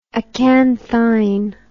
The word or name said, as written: acanthine